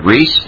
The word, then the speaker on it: Rees: The name is Kent Reese, and it's spelled R-E-E-S.